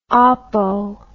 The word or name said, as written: apo